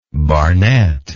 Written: Barnett

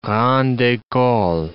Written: grande ecole

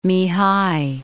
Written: Mihai

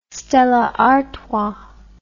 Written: Stella Artois

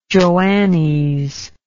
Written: Joannes

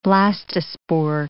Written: blastospore

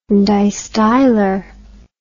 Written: distylar

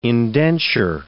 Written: indenture

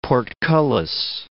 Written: portcullis

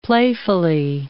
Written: playfully

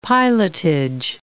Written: pilotage

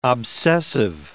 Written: obsessive

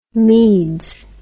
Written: meads

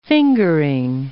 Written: fingering

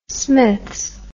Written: smiths

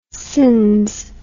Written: cins

